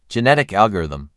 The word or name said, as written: Genetic algorithm